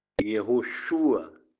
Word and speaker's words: Yehoshua: A sílaba tônica na primeira vogal, no U, Yehoshua, né? O A fica mais ou menos com o som de uma semivogal, tá bom?